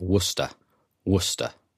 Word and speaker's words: Worcestershire: The famous sauce is pronounced Worcester, Worcester. I know it's totally counterintuitive, but I guess that's because the pronunciation has stayed the same while modern spelling has changed. It's similar to Leicester, which is spelt Leicester or Leicester, but pronounced Leicester. There are lots of British place names that are pronounced totally differently to the way they're spelt, at least according to modern spelling.